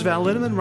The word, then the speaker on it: Linneman: Alicia Lemus, Rebecca Hughes, Val Linneman, Rhymes with Cinnamon,